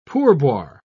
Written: pourboire

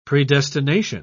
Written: predestination

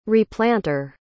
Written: replanter

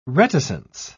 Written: reticence

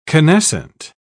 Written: canescent

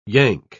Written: yank

